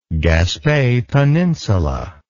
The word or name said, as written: Gaspe Peninsula